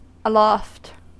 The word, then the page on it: aloft: American English